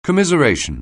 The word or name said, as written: commiseration